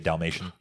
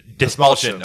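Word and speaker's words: Dastmalchian: I don't know how to say his last name, so I've just been calling him David Dalmatian. – Dismalchin. – Okay. – Dismalchin. – That's way cooler than Dalmatian. – Yeah. – And for the record, I knew it wasn't Dalmatian.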